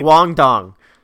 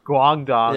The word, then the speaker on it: Guangdong: Oh my God, he signs with the Chinese team, um, Gongdong for two months. Is that is that how you would pronounce uh, the name? I'd probably go team? Guangdong. Guangdong.